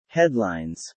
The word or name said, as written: headlines